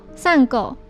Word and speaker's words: 珊瑚: 步美曾对横沟说过，他的头发很像珊瑚，日文念作散狗。